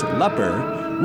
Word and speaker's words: LPHR: And as we train our radio eyes on public station LPHR, invariably pronounced leper, we see unfolding before us the next episode in the career of Craig Bellows, oblivious radio station front desk receptionist.